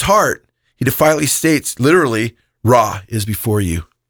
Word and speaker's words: Ra: Ra.